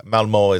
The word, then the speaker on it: Malmö: Yes, and I was in Sweden last year, so yeah, Malmö is the correct pronunciation.